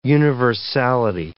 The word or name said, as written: universality